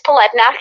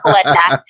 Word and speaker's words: Polednak: Yes, it's Polednak. It's not. I don't know why that name stresses people out. I get it. There's a lot of consonants happening, but yeah, Polednak. That's how you say it.